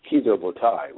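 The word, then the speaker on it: Kido Butai: I think you pronounce it Kido Botai, which is the Japanese reference.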